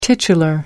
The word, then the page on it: titular: American English